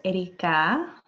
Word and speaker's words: Erikaa: My name is Erikaa. You do not have to roll your R's. – I love to. It's fun. – Focus on the ka.